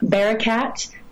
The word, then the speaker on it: Barakat: My name is spelled a little bit different than most, but it's Hannah, H-A-N-N-A, Bearcat. Which is B-A-R-A-K-A-T, and they can find me on all the things.